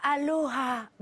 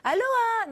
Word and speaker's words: aloha: On ne dit pas aloha, non, on dit aloha.